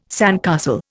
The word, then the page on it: sandcastle: Indian